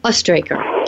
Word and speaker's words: Oestrecher: So, folks, you're listening to Amy Oestrecher. Did I say that right, Amy? – Amy Oestrecher. You did.